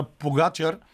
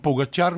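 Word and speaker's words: Pogačar: Може би, може би най-интересното нещо в тях беше падането на Погачар. Както си вие също му казвате в Евроспорт, аз бях свикнал да викам Погачар, но разбрах, че Погачар е най-правилно. Даже слушах специално словенско произношение.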